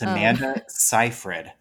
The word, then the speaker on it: Seyfried: It's Amanda Seyfried. I googled Seyfried.